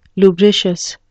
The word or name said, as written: lubricious